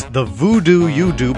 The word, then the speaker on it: Voodoo Yoodoo: But this one is a song written by Scott Ambush, and it's not spelled like it sounds. It's the voodoo you do, but it's spelled V-O-O-D-O-O-Y-O-O-D-O-O.